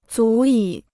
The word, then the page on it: 足以: zú yǐ